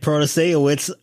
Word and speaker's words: Protasiewicz: The Wisconsin candidate, Janet Protasiewicz, patting myself on the back for correctly pronouncing that finally,